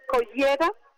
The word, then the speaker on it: Collioure: porque luego esta tarde nos vamos a Collioure también. Bueno, no se pronuncia exactamente así, me lo van a disculpar, pero aquí se dicen Collera.